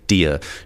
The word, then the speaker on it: Dear: The Sprayed Deer. Spricht sich aus wie Englisch Deer für Hirsch, schreibt sich aber mit EA wie My Dear, also meine Liebe oder mein Lieber oder auch mein Herz.